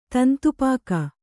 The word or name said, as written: ತಂತುಪಾಕ